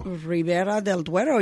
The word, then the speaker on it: Ribera del Duero: Ribera del Duero. Your pronunciation is not bad at all.